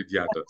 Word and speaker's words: Diatta: Bacary Diatta.